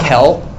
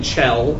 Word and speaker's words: Chell: Kel? – I think that's an acceptable pronunciation. – Yeah, that could be. – English pronunciation. – Yeah, it's actually Chell, but you know. – Okay. – CH can be pronounced as a hard, so yeah, that's fine.